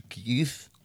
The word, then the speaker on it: Keeth: c'était Kiss avec euh, Road Trip, donc K-E-E-T-H, Kiss.